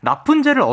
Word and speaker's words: 라푼젤: 라푼젤을 어떻게 발음한다고요? – 라푼젤.